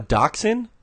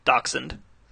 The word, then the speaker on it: dachshund: Yeah. Dachshund. – I have been pronouncing this dog name incorrectly my entire life. A dachshund. Why is it spelled like dachshund? – It's like D-A-C. The doc is the D-A-C part. – Doc. Sund. – Sund. – Sund. – It's a German word, I believe.